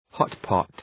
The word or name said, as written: hotpot